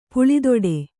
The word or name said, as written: ಪುಳಿದೊಡೆ